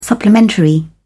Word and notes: supplementary: British English

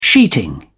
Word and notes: sheeting: British English

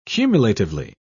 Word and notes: cumulatively: British English

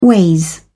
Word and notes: ways: British English

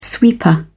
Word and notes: sweeper: British English

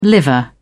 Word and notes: liver: British English

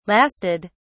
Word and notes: lasted: British English

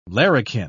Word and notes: larrikin: British English

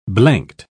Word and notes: blanked: British English

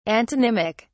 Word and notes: antonymic: British English

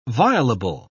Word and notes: violable: British English

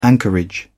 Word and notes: anchorage: British English

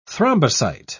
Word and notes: thrombocyte: British English

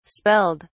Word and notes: spelled: British English

